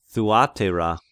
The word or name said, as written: Thuateira